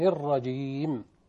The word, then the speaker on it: الرجيم: البعض يخطئ الرجيم أو يغنها الرجيم، أو يجيب ياء قبل الميم الرجيم، كل دي أخطاء. لما النطق الصحيح أعوذ بالله من الشيطان الرجيم، بسم الله الرحمن الرحيم.